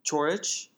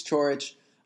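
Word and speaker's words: Ćorić: Commentators were saying Chorich. Uh-huh. So let's go with that. – Borna right, Chorich. We really don't want to butcher his name. We think it's Chorich.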